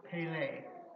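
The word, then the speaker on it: pele (פלא): The Hebrew word for wonderful is pronounced pele, which is related to the word miracle, or amazing, marvelous, or extraordinary.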